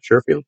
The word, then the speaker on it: Sherfield: Sherfield? – Yeah, Trent Sherfield had the one in Miami.